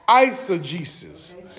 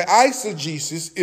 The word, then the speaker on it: eisegesis: Now, the opposition word, mother, I know you've been waiting on this, is called eisegesis. Somebody say eisegesis. Okay, eisegesis is spelled E-I-S-E-G-E-S-I-S. Eisegesis. E-I-S-E-G-E-S-I-S.